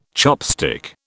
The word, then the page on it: chopstick: British English